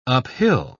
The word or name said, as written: uphill